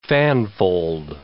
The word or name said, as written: fanfold